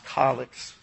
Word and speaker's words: calyx: I know that can be pronounced calyx with a softer A sound to it.